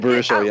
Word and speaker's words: Baruchel: And Jay Baruchel, am I saying that right? – But Jay Baruchel, yeah.